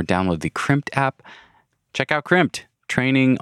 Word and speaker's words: Crimpd: Crimped is spelled C-R-I-M-P-D.